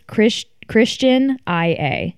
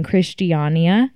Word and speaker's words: Christiania: It's spelled to the American eye. It's Christian IA. Mm-hmm. So we kept saying Christiania. But they say it like Christiania.